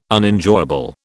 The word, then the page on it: Unenjoyable: British English